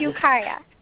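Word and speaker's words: Ukiah: We have Yukia, California, and hopefully I said that right. Are you there? – I'm here, and thank you, goddesses. – Good. – It's Yukia.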